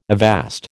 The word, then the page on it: avast: American English